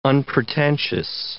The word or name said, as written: unpretentious